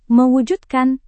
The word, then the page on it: mewujudkan: Indonesian